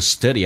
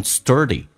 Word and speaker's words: study: I think I need to study at home tonight。 study 这个单词很多人喜欢卷舌，卷成 sturdy，不要卷舌，舌头很放松。 study， study 跟 at 有一个连音，元音跟元音之间的连音不是加我就是加也，那这个加个也叫 study it，